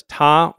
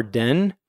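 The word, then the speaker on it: ta (田): And it's pronounced in Japanese as ta or den.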